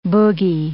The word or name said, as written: boogy